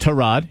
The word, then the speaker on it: Tyrod: And then with the Browns, you have Tyrod Taylor, which I believe is now pronounced Tyrod. He corrected everyone after like 8 years in the league.